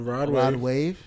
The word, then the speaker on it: Rod Wave: No, Roddy Wave. I feel like I'm saying that wrong. – No, Rod Wave. – Rod Wave. Right. Okay.